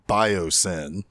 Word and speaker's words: Biosyn: And oh, by the way, this company's name, Biosyn. Now, it's spelled B-I-O-S-Y-N, but come on, guys, Sin is in their name.